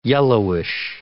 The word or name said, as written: yellowish